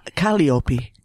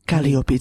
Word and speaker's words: Calliope: Calliope. Calliope. It's really, I pronounce it Greek way. The Greek way, yeah.